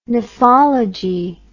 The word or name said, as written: nephology